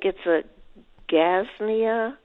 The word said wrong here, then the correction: Gazania: Is that correct pronunciation? – Gazania, maybe? – Yeah, probably so, yeah.